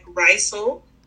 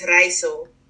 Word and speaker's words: Rijssel: So you will say in English like Rijssel, but the Dutch way to say it is Rijssel.